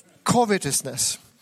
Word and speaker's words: covetousness: What Solomon is talking here about, he's talking about covetousness. It's a very difficult word for a Ukrainian guy to pronounce. Covetousness. I actually made myself 4 syllables to try to understand how it should be pronounced.